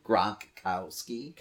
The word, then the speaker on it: Gronkowski: Gronkowski.